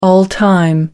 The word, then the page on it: all time: American English